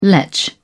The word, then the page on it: letch: British English